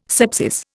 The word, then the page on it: scepsis: Indian